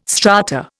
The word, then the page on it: strata: Indian